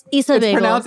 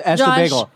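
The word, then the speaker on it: Essa Bagel: It's Issa Bagel. – It is like literally pronounced Esa Bagel. – It's spelled E-S-S-A Bagel.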